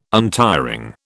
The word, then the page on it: untiring: British English